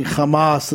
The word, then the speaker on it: Hamas: And I'm glad that he was pronouncing Hamas the same way as people pronounce hummus.